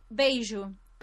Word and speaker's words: beijo: I would just make the J sound like an H. Well, how do you say that word? – Beijo.